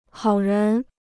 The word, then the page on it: 好人: hǎo rén